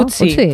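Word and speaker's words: Ötzi: Otzi, creo que se llamaba, un nombre muy, muy noruego. – Utzi, ¿no? – Utzi, pues sí, Utzi.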